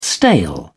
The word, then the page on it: stale: British English